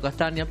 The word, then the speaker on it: Castagna: En su nombre real se escribía Castagna, pronunciado Castaña, pero Castaña con ñ era parte de su nombre artístico.